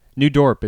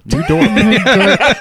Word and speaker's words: New Dorp: It's spelled how you think it's spelled. – D-O-R-P? – Okay. D-O-R-P. – Or D-O-R-P-E? – Right. No E. Just Dorp. – Just Dorp. – It's not Dorp. – Or D-O-O-R-P.